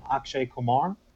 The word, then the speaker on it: Akshay Kumar: Um, I would pronounce his name um, Akshay Kumar uh,